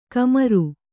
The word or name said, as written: ಖಮ್ಮರಿ